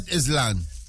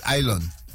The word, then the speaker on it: island: So it's not Islan. It's island. – Right. – The S is silent.